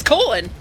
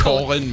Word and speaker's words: Colon: It's Colon. – Are you serious? – Yeah, that's literally pronounced Colon, Michigan.